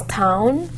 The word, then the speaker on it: tuin: Okay, so I think the pronunciation is something that probably escapes me as well, because I'd learnt it by heart, and I think it's tuin, T-U-I-N, it's a Dutch word.